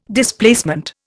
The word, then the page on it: Displacement: Indian